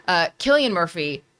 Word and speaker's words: Cillian Murphy: Well, first of all, Cillian Murphy, excuse me for butchering his name earlier. Cillian Murphy, ever since he was Scarecrow, he has He has struck fear through my body with a simple look.